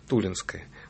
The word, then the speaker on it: Тулинское: И можно прямо сказать, что действительно ударение Тулинское.